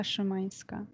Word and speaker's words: Szymańska: My name is Marta Szymańska.